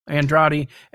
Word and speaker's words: Andrade: Andrade, and I'll have that in the show notes.